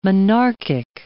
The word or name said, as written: monarchic